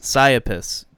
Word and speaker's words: Psyopus: Psyopus. P-S-Y-O-P-U-S.